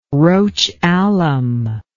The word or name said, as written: roche alum